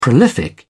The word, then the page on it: prolific: British English